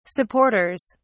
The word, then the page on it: supporters: British English